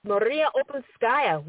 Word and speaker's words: Uspenskaya: Maria Uspenskaya.